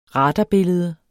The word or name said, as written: radarbillede